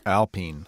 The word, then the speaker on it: Alpine: It's not Alpine. – No. – Alpine. – No. – Alpine. – No. – Alpine. – Alpine. – That's the right way to say Alpine. – And it looks like it's spelled like Alpine, but yeah.